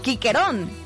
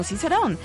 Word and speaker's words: Cicerón: Y en el latín antiguo la C sonaba siempre como una K, así que el famoso Cicerón, el gran orador romano, se pronunciaba más o menos como Quiquerón, no Cicerón.